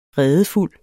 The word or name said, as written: redefuld